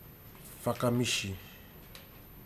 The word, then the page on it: fakamiji: futuna aniwa